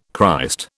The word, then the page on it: Christ: British English